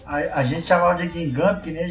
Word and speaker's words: Guingamp: O jeito certo de falar é Guingamp, né? – É.